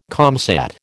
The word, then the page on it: comsat: American English